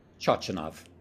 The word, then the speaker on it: Chochinov: Chochinov.